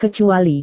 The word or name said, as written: kecuali